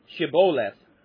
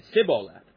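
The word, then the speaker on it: Shibboleth: And when he said, "No," they said to him, "Then say, Shiboleth." And he said, Shiboleth, for he would not pronounce it right. He said, Siboleth, for he would not pronounce it right.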